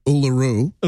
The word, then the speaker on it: Uluru: Am I not saying it right? – Oh, this is great. – How do you say it? What do you say? – Say it again. – Uralura? Uralura? How do you say it? – Uluru. – Uluru. Okay. I'm just repeating what I was told. – Yeah, it's Uluru.